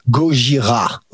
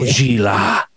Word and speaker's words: Gojira: It's pronounced Gojira. Okay. – Gojira.